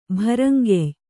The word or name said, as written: ಭರಂಗೆಯ್